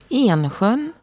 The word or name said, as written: Ensjön